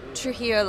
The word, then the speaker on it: Trujillo: And then just tell us a little bit about your hometown, Trujillo, you said, right? Is that how you pronounce it? – Yes. – Yeah. What is Trujillo like, and what do you like most about it? – Yeah, I mean, it's a small city.